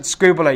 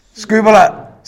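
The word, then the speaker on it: skubala (σκύβαλα): It's spelt like the first one, but you pronounce it skubala. You're going to say this after me? 1, 2, 3. Skubala.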